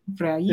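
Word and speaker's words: Freire: We got how do you pronounce his last name? Freid? You know what? I probably should have practiced that before I come on here. Freid? – Yeah. – Well, Freid.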